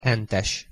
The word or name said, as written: hentes